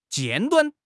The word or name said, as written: 尖端